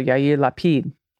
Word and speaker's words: Yair Lapid: Yair Lapid. I'm so bad. I'm a bad Jew. Yair Lapid. Yep. And also with accent on the final syllable. So Yair Lapid.